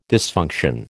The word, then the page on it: dysfunction: American English